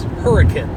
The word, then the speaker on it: Hurricane: And it's spelt like the word hurricane. And a lot of people might call it that, but the actual pronunciation is hurricane, which is kind of weird.